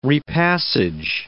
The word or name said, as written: repassage